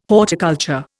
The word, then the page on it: horticulture: Indian